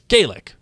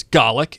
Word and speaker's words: Gaelic: Gaelic, when pronounced with a long A, is Gaelic, and that is Irish. Gaelic, when pronounced with a short A, is Gaelic, and that is Scottish.